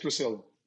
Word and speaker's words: Priscilla: How do you pronounce your wife's name? – My name is Priscilla.